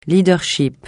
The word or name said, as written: leadership